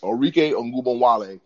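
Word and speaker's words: Arike Ogunbowale: And also, the other one, my other moment is Orike, I'm going to butcher his name, Orike Ogunbowale. Yes, I got it right. Yes, Orike Ogunbowale,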